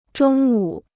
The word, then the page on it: 中午: zhōng wǔ